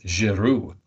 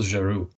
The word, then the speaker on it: Giroux: So the way it did for me is that in the opening credits, one of the last names was Giroux with an X that you don't pronounce.